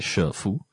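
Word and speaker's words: shifu (师父): is sometimes the word shifu, or you have to read it as shifu, or pronounce it as shifu.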